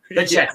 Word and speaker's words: Pacheco: No, it's like, what is Isaiah Pacheco? – Pacheco. – That's how you pronounce it, but Pacheco.